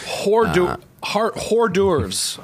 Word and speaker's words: hors d'oeuvres: Hors d'oeuvres, I believe it's pronounced. – Hors d'oeuvres.